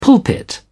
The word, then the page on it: pulpit: British English